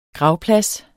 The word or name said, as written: gravplads